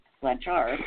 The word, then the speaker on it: Blanchard: I mean, the medical community, they relied on Dee Dee Blanchard, that's how you pronounce it, I was mispronouncing it the whole time, they relied on her to be the historian for her daughter.